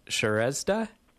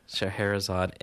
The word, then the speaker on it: Scheherazade: Now, please don't kill me if I mispronounce this. Shrezda? – Scheherazade.